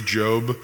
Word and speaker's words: Job: And so our graphic for this series puts the macron over the letter O, you know, the long O, so that you can remember it's Job.